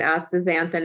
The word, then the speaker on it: astaxanthin: Okay, I'm going to get the astaxanthin. Did I pronounce it correctly? – Astaxanthin, yeah.